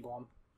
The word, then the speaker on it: Blum: His name is supposed to be Blum.